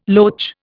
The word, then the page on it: loach: Indian